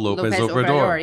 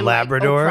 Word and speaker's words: López Obrador: Andres Manuel López Obrador. – Yeah, would she call him Labrador? – Like, Obrador?